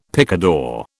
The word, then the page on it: picador: British English